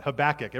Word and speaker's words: Habakkuk: Uh, next week, we're going to be heading into a eight-week series covering the minor prophet book of Habakkuk. Yeah, you're like, what? That's a book? Yeah, that's a name of minor prophet. Habakkuk. Everyone say Habakkuk.